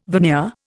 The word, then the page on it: veneer: Indian